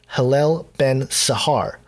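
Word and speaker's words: Helel ben Shahar: It actually translates to Hillel ben Ashur, or sorry, I totally butchered that, Hillel ben Sahar.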